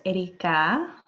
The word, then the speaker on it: Erica: My name is Erica. You do not have to roll your R's. – I love to. It's fun. – Focus on the ka.